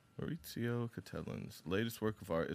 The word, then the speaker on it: Maurizio: It may be a Maurizio. – Maurizio. – There's no T, but it might be pronounced like that. Which I like that. – Is there a Z? – Good content. No, it's a Z. – That's Maurizio. What I said.